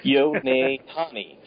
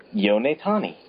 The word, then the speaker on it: Yonetani: The guy who directed this film is Yoshitomo Yotanami. Okay, I think I'm saying that right. Yonetani. Okay, Yonetani.